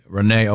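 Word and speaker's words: Renée: Dear Bob and Tom Show, I don't know if this is a Renee or a Rennie, because there's an accent on the E at the end there. – Oh, then you got to go Renee. – Renee, okay.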